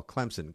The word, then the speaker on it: Clemson: Clemson, great area. There's no P in there, Andy, but definitely pronounced as well.